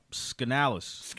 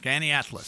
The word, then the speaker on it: Skaneateles: Your background growing up, Skaneateles. – Skaneateles. – Skaneateles. – Skaneateles. You can spell that. You get into the second grade. – Oh, because I've been thinking Schenectady, Schenectady. – Skaneateles.